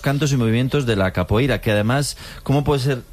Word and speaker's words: capoeira: Pues todos sabemos lo que es la copeira, ¿verdad? Capoeira. Qué mal pronuncio últimamente. – Capoeira.